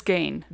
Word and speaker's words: Gein: Three different pronunciations. – We just had three different. So it's Gein.